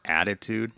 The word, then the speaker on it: Additude: It's called the ADHD Experts Podcast, and it's hosted by, I guess it's like an online magazine that I've been following forever called Attitude, but it's spelled ADDITUDE.